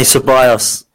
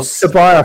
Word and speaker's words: Ceballos: Is it Ceballos? Potsy, is it Ceballos? Ceballos? – Danny Ceballos. Fergus always does it really well. – Ceballos.